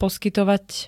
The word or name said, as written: poskytovať